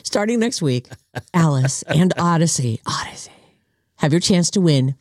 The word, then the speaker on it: Audacy: It's spelled A-U-D-A-C-Y. It's tricky. It autocorrects. Odyssey is the name of the app.